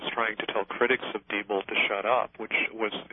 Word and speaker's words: Diebold: How do you pronounce it? I've always said Diebold, but no, it's Diebold. It's Diebold, actually.